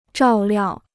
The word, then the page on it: 照料: zhào liào